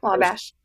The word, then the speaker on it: Wabash: How do you say that? – Yeah, Wabash.